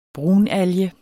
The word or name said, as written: brunalge